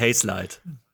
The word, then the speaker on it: Hazelight: Du hast das Studio falsch ausgesprochen. – Naja, also es heißt halt Hazelight.